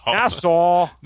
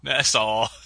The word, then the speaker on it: Nassau: Just change the U to a W and you'll know how to pronounce it. That's all. – Nassau. – Nassau.